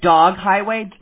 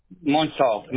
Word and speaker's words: Montauk: You said Dog Highway, like D-O-G? – Montauk. No, Montauk. – Oh, okay. How do you spell that? – M-O-N-T-A-U-K.